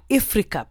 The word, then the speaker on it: Ifrica: I said it right, Ifrika, because it's spelled I-F-R-I-C-A.